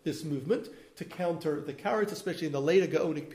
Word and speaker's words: Karaites: Karaites, Kraim in Hebrew.